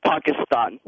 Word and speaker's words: Pakistan: It's pronounced by people from that area. It's Pakistan. – Pakistan. – Pakistan. – Pakistan. – Yep. So you say Pak, like Tupac.